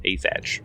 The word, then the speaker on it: Athach: How do you spell that? – A T H A C H, Asach.